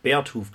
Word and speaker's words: Beartooth: Beartooth, glaube ich, wird es ausgesprochen. Beartooth, ja, okay, gut.